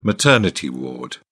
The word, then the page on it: maternity ward: British English